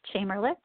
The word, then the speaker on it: Chamerlik: And my name is spelled Erin, E-R-I-N, Chamerlick, C-H-A-M-E-R-L-I-K.